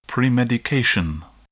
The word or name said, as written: premedication